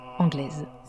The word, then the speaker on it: angloise: qu'on écrit « angloise » mais qu'on prononce « anglaise ».